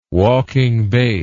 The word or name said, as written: walking bass